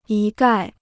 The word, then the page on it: 一概: yī gài